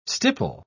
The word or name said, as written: stipple